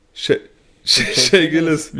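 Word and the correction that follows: Shai Gilgeous: Wie wird denn der ausgesprochen? – Shay Gilgeous.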